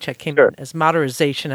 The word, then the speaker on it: maderisation: And just for listeners, just if you're wondering what this word is, matterization, I'm going to spell it for you. It's MADERISATION. So there's no Z in there.